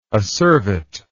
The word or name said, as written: acervately